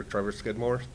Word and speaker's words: Trevor Skidmore: Officer Trevor Skidmore, TrevorSkidmore.